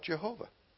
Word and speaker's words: Jehovah: The Germans pronounce it as a Y. So this is where we get Jehovah from.